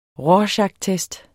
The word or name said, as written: rorschachtest